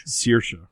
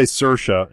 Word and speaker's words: Saoirse: It's Saoirse, right? – I'm messing up, but it's because people say Saoirse and it's Saoirse.